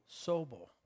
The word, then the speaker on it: Sobel: Sobel.